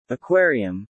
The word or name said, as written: aquarium